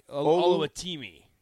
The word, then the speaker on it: Oluwatimi: Olu Oluwatomi. Am I saying it right? – Olu Oluwatomi. Oluwatimi.